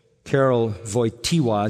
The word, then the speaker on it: Karol Wojtyła: After the death of his mother, when he was 8 years old, Karol Wojtyła is how you say his name, the Pope that died.